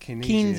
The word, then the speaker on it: Keynesian: Like Keynesian, how do you pronounce it? – Keynesian. – Yeah.